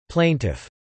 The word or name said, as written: plaintiff